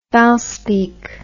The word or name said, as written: Valspeak